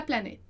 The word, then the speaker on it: planète: mas só para relembrar: planète, planètes. Então, esse T ali, ele é meio que mudo no final, né? Ele é muito sutil.